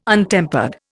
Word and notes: untempered: Indian

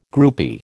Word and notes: groupie: American English

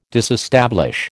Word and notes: disestablish: American English

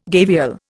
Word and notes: gavial: Indian

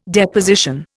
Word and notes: Deposition: Indian

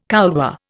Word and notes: couloir: Indian

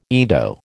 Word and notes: Ido: American English

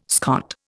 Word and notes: Scot: Indian

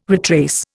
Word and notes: retrace: Indian

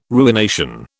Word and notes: ruination: British English

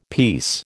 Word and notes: peace: American English

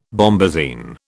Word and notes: bombazine: British English

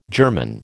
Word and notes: German: American English

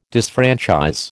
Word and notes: disfranchise: American English

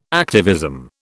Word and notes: activism: British English

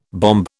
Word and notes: bombe: British English

